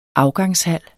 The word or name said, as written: afgangshal